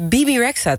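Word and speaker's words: Bebe Rexha: Bibi Rexha, toch? – Echt waar? – Ja. – Oh? Nee, maar Bibi, dat is die engert van... – E spreek je toch als I uit? – Huh?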